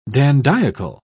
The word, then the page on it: dandiacal: British English